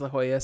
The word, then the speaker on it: La Jolla: La Jolla. It is La Jolla.